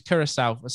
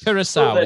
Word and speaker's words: Curaçao: Carrizo, the correct pronunciation is Curaçao.